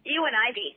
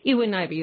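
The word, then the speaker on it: Eowyn Ivey: Yeah, and how do you pronounce that name? – Ewan Ivey. – Ewan Ivey, that's right.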